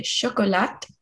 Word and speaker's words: chocolat: Il aime de manger les chocolats. – Il aime les chocolats. – Ah, très bien. D'accord. – Les chocolats. Chocolat. – Chocolat. Chocolat. – The tea is silent.